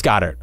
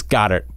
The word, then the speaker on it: Goedert: And it's Goddard. It's Goddard. I'm going to go with Goddard. That's the official counterpoint pronunciation of Dallas Goedert. It is Goddard.